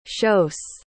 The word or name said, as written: chausse